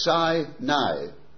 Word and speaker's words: Sinai: I notice a lot of you say Sinai. It's not Sinai, it's Sinai. A-I is I, so it's Sinai, all right? Now, don't disagree with me, because I'm absolutely right on that. That is the proper pronunciation. You can pronounce it whatever way you like, but I just thought I'd tell you the right way to do it.